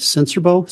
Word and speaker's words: Sincerbeaux: My name is David Sincerbaux, spelled S-I-N-C-E-R-B-E-A-U-X.